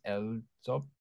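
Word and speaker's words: EL7Z UP: And using the Korean spelling, it's supposed to be pronounced L-Zop, like L-Zop.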